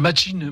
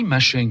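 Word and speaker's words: Machín: Machin, est-ce que je prononce bien Non, c'est Machine, mais. non, mais c'est normal. Tu l'as prononcé à la française, ce qui est très bien. Mais en fait, ça s'écrit Machin. C'est là où tu as raison. Mais on prononce Antonio Machin.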